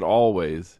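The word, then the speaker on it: Alvvays: And you do pronounce it Always. They spell it with two V's instead of W. So like it looks kind of like a W. You know, it's got the two V's next to each other.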